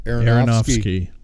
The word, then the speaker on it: Aronofsky: Aronofsky.